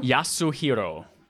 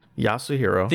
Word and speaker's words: Yasuhiro: What was that? Yoshiro Kitao? – Yasuhiro. – Yasuhiro. – Think of him in like two letter syllables.